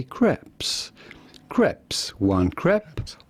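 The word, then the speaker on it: crêpe: In French, we would say crepe. – Crepe.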